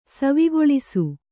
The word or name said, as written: ಸವಿಗೊಳಿಸು